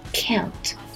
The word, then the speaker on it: count: 那它的发音呢，先是一个双元音 l，再是这个 n，最后才是清辅音 t。 count。所以说不要吞音，可 l count 再加 n 再加 t count。